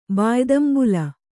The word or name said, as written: ಬಾಯ್ದಂಬುಲ